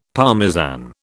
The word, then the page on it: Parmesan: British English